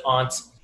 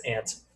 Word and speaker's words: aunt: We pronounce it aunt in Boston. You might hear it as aunt for people who aren't familiar.